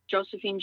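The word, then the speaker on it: Josefine: So on Facebook, you can find me, Josephine Janes for State Assembly.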